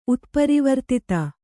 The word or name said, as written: ಉತ್ಪರಿವರ್ತಿತ